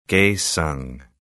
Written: Gaeseong

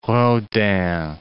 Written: Rodin

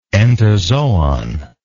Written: entozoon

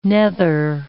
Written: nether

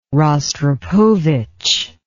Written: rostropovich

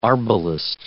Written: arbalest

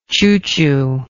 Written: choochoo

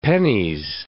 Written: pennies

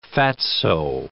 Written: fatso

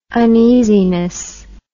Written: uneasiness

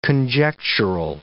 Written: conjectural

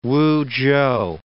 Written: Wuchow

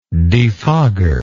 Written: defogger

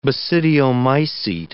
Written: basidiomycete

